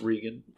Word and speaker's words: Regan: It's pronounced Regan.